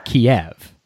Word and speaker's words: Kyiv: I guess, is it a mispronunciation to say Kiev? – So the K-I-E-V is the transliteration of Russian, and the K-Y-I-V, Kyiv, is the transliteration of Ukrainian.